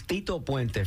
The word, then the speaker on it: Tito Puente: This is Tito Puente from 1962.